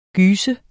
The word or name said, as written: gys